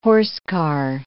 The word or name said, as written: horsecar